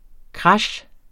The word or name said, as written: krash